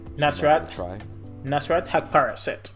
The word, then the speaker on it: Nasrat Haqparast: Nasrat Haqparast.